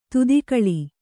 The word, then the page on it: ಧಾತುಗೊಳ್: dhātugoḷ